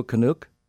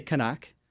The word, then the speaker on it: Canuck: Is it rude to call you a Canuck? – Well, you'd have to pronounce it right. – Oh, sorry. – You'd have to say Canuck.